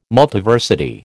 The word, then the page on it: Multiversity: American English